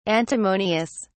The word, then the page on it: antimonious: British English